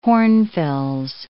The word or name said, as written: hornfels